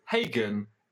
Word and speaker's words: Hagen: We have a Kurtis Hagen paper, because Hagen. – His last name rhymes with Reagan, as I discovered.